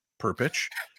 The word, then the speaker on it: Perpich: It's like, yeah, Governor Rudy Perprick, Perpich uh, yeah, Greg Gagne, uh, Gagne.